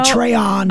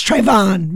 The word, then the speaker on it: Trayvon: Do you remember when Jesse Jackson mispronounced Trayvon's name? He said Trayon. – Oh, man. – And someone had to tap him on the shoulder and say, it's Trayvon, Reverend.